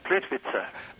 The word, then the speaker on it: Plitvice: The lake is Plitvice. – Plitvice.